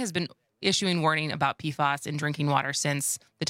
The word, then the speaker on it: PFAS: Google says, by the way, it's PFAS with a short A.